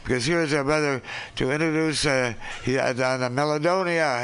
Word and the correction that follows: Melodica: Is that how you say it? – The Melodica.